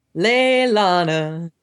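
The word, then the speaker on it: Leliana: I keep calling her Leilana. I keep getting the I and the L switched for some reason in my head. I'm getting confused and I'm like, am I saying it wrong? – No, you're saying it right.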